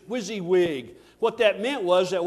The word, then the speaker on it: WYSIWYG: And it spelled this on the screen, W-S-I-W-Y-G. What you see is what you get. And they pronounced it WYSIWYG.